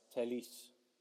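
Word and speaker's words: Telles: You need to pronounce it right, Telis. Telis.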